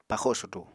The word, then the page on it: paxo sudu: Apatani